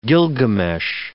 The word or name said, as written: Gilgamesh